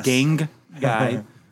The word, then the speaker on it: gang guy: Gang guy. Make sure I say that right. – Yeah, because if you say gay guy, you're talking about everybody in this movie.